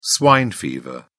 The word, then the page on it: swine fever: British English